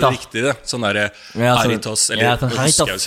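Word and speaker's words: Jarritos: Visste du at jaritos på spansk haritas? Det sånn det uttales.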